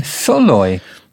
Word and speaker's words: Szalay: So it's David Soloy, apparently. – Soloy? – Yeah. That's how you say the name, Soloy. – Oh. – But it is spelled S-Z-A-L-A-Y.